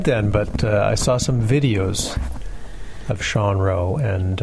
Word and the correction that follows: Rowe: he pronounces it Rao, by the way.